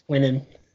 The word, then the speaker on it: Quinnen: But because I kept thinking that Greg McElroy was saying it incorrect for so long, and I was like, oh no, I mean, he's paid to do this, so he's probably saying it right. And then I saw, oh, okay, I thought he just had like a speech impediment or something, but it is Quinnen.